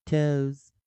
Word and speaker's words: toes: 所以你看到 o e 可以发 o 或者 u 啊，toes，toes，toes，shoes，shoes，shoes。所以在这个地方看起来都像 o e 结尾，有复数的发音的方式。再来一遍，toes。 toes, toes, shoes, shoes, shoes, goes, goes, goes, does, does, does。